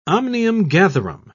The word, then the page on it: omnium-gatherum: British English